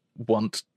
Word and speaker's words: Onett: Is that how it's pronounced? Onet? Or is it like Want? Onet? – It should be Want, kind of, because, you know, all of the town names in Earthbound are like sort of like fun little plays on things.